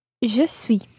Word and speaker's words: je suis: Je suis. – Je suis. Je suis. – Je suis. – So that's S-U-I-S.